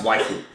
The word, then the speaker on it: waifu: I think it's pronounced waifu.